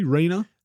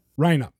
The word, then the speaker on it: Reina: We're here with the chairman of the planning board, Drew Reina. Actually, am I correct? Am I pronouncing your surname correctly? Reina? – Yep, Reina. – Reina.